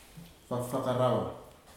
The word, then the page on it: fafakaraua: futuna_aniwa